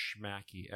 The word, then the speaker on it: Shmakee: And a lot of times people will pronounce it Shmakee. We're not quite sure, but it's pronounced Shmakee. S-H-M-A-K-E-E.